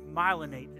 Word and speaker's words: myelinate: There's this word called malinate. I think myelinate is how you say it.